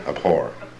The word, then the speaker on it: abhor: It's got an H. – You pronounce the H? – Yeah. Abhor.